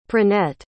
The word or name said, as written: pranet